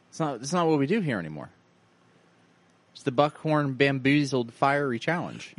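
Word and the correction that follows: BeanBoozled: You can't even say it right. – Buckhorn Bean Boozled Fiery Challenge.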